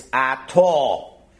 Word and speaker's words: Atah: So although an Israeli would pronounce the second word Atah, you may not. It's Atah.